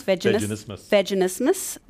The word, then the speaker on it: vaginismus: Vaginismus.